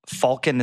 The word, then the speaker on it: falcon: Falcon is the way to say it. Wow. The, the L is silent and it's actually like Falcon, like walk or talk. You don't say the L.